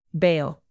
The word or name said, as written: bail